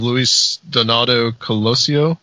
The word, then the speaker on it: Luis Donaldo Colosio: We were talking about a couple of things from the 90s, Chupacabras, and then you mentioned the assassination of Luis Donaldo Colosio.